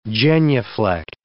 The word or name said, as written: genuflect